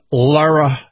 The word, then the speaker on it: Lara: All right, well, it's L-A-R-A, so I'm going to pronounce it Lara.